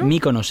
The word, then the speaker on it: Míconos: ¿Es Míconos o Míconos, tío? Míconos se, pronuncia.